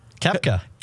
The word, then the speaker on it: Koepka: It's how do you say the K guy's name? – Koepka. – Okay. – Brooks Koepka.